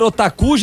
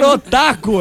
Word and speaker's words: otaku: Otaku se escreve O-T-A-K-U. – O-D-A-C-U. – O-T de teta. Ah, otaku.